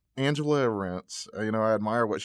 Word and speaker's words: Arendt: Arendt.